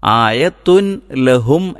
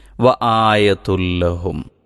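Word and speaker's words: وَآيَةٌ لَهُمْ: ആയത്തുൻ ലഹും എന്നല്ല, മറിച്ച് വ ആയതുല്ലഹും ഒന്ന് പറഞ്ഞു നോക്കിയാൽ നമുക്ക് പിന്നീട് എല്ലാ ഭാഗത്തും ശ്രദ്ധിക്കാൻ പറ്റും. വ ആയതുല്ലഹും, വ ആയതുല്ലഹും അന്നാ ഹമൽനാ, ഈ രൂപത്തിലാണ് അത് പാരായണം ചെയ്യേണ്ടത്.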